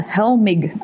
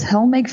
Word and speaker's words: Helmig: And apparently it's a German family called Helmig. I think that's how you pronounce it. H-E-L-M-I-G.